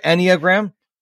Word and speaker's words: Enneagram: Did I say that right? Enneagram? Yeah, yeah. Just Enneagram is okay.